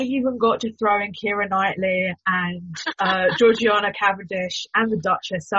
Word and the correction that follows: Georgiana: I am I knew you were going to pronounce it wrong. I was looking at the notes and I was like, apparently it's Georgiana. – Yeah. – Okay, sorry, Georgiana Cavendish.